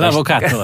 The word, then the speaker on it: L'Avvocato: L'Avvocato, ja.